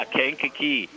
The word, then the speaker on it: Kankakee: Another character in the story is the city of Kankakee, Illinois. Am I saying that correctly? – Yeah, Kankakee.